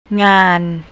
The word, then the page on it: ngan: thai